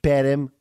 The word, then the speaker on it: Perm: Perm, it's pronounced Perm, Russia.